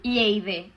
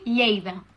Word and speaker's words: Lleida: Que no es diu Lleida, es diu Lleida. El que passa és que els de fora de Lleida no sabeu pronunciar aquest final de paraula, i és normal, és que idiomes, querida.